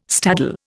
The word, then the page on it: staddle: Indian